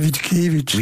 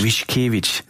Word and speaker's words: Witkiewicz: Øh, du fortsætter da i hvert fald, kan man sige, øh, øh, interessen, og det er jo så Stanislav hvordan udtaler vi ham? Vitkevich? Nej, Witkiewicz. Witkiewicz.